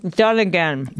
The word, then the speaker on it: Dunnigan: Dunnigan.